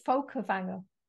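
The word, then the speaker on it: Fólkvangr: And so in Norse mythology, I know it said there are 9 realms and that Freyja rules over, I'm going to mispronounce this. It's like Folkvanur. where she received, I think it's Folkvanger.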